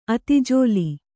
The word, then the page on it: ಅತಿಜೋಲಿ: ati jōli